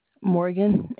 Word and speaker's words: Morgan: This is Morgan speaking. How can I help you? – What did you say your name was, Gloria? – Morgan, M-O-R-G-A-N.